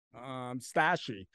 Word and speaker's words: Stashii: It's called Stashy. It's called Stashii is how it's spelled. It's called Stashy. So what it is essentially, it's a like air B&B type thing for your storage is what it is.